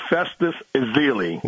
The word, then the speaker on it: Festus Ezeli: Festus Ezeli.